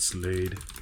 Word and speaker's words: Slade: Director David Slade. Rhymes with Blade.